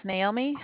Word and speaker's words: Naomi: My name is Naomi.